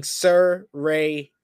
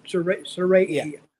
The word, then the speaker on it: Saraya: She put out the pronunciation on her Twitter page saying Saraya. And they did to Saraya. – Okay, Saraya.